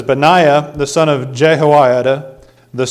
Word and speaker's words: Benaiah: His name, I had to look up how to say it, Benaiah. is how that fancy mouth book says you're supposed to pronounce it. Benaiah.